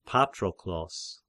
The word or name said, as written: Patroklos